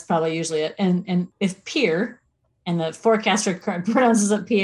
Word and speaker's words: Pierre: Our state capital it's pronounced around here, Pierre. It's Pierre. I mean, that's how it's written, Pierre.